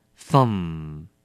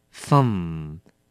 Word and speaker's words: thumb: 第八个字 thumb, thumb, 名词拇指。再次提醒您 MB 在一个字尾结束的时候我们只发 M, B 是不发音的。